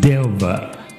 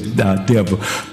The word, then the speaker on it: Delva: Delva. Okay, I'm looking in my glass. I don't know why I saw an eye right there, but it's Delva.